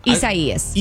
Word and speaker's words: Isaias: Growing boy, that Isaias. – It really is. – Am I saying it right? – Yes. – I'm not doing it. Isaias? – Yes. Isaias.